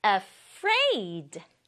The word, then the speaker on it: afraid: 소리 나는 A랑 fur, fr 만나서 fr, 그리고 ai 만나서 a, 마지막으로 d, afraid. 아예 강조 주지 않고요. raid, 요 부분이 강조예요. afraid, afraid 이렇게 해야지 무서워하는을 제대로 발음할 수 있다.